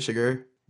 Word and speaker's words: Chigurh: And Javier Bardem's Anton Chigurh. Is that how you say it? Chigurh? – Yeah.